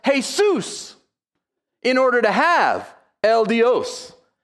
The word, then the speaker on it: El Dios: Am I saying that right? El Dios? Is that right? Yeah, close enough.